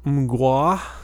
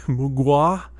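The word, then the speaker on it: Mgła: Um, most of you probably know them because their name is spelled very different than how it's pronounced. So it's spelled M G L A, but that's pronounced Mgwa. – Mgwa. It's the Polish word for fog, and um, they kind of.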